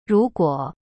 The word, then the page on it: 如果: ru guo